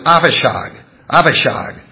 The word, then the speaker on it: Avishag: but there's a dot in it, it's a B sound. If the dot is not there, and you have the letter B, it's a V sound. So the way you actually pronounce her name in Hebrew is Avishag, the Shunammite, and brought her to the king.